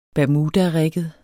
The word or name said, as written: bermudarigget